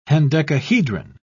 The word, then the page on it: hendecahedron: British English